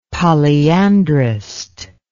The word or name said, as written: polyandrist